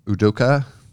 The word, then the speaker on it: Udoka: I guess the first one was M.A. Udoka. Is that how you say his name? – Yeah, that's pretty good.